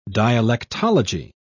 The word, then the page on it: dialectology: British English